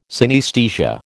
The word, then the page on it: synaesthesia: American English